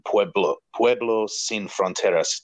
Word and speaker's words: Pueblo Sin Fronteras: The main organization behind it seems to be Pueblo Sin Fronteras. Did I pronounce that correctly, Luke? – Pretty close.